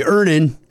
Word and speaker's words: Earnin: Earnin. – Earnin. – E-A-R-N-I-N is pronounced Earnin.